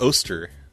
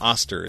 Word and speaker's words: Oster: And, okay, then I did Oster. Not Oster, it's Oster. I found out that it's pronounced Oster.